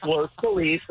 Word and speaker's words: Los Feliz: It's Los Feliz. I'm sorry everyone in the world pronounces it wrong.